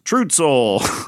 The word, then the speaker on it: Trutzel: It's true, T-Z-E-L. Well, how would you say that? Why don't you give it a try? – Okay, I got this one, guys. It is Trutzel.